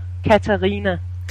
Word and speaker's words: Katharina: How do you say your first name? Is it Katharina? – Um, it, Danish is a very hard hard language, so uh-huh. it's Katharina.